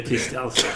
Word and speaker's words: Kristianstad: Ja, men det riktiga namnet är Kristianstad. – Kristianstad.